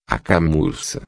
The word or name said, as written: camurca